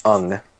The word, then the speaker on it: Aang: And she will be referring to Aang, who is since deceased because obviously... – I think it's pronounced Ung. – Ung. So says the movie.